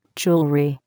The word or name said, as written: jewelry